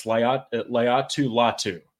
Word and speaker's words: Laiatu Latu: It's Laiatu Latu.